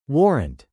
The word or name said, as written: warrant